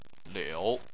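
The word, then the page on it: liu: tone 3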